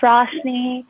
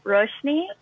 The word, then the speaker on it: Roshni: Hi, my name is Roshni. That's the Americanized version. The actual pronunciation is Roshni.